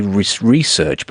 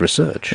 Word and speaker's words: research: But then that's putting the stress on the last syllable, right? The research one is more American, put the stress on the first syllable.